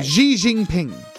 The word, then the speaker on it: Xi Jinping: Xi Jinping is how you pronounce it.